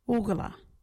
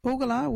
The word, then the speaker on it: ugarla: Oogala. You can use Oogala in this sentence. Oogala we used to live in the bush. Oogala is spelt U-G-A-R-L-A.